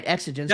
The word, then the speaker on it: exigence: You talk about, I think I'm saying the word right, exigence. – Yeah, you're saying it correctly.